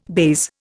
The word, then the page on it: baize: Indian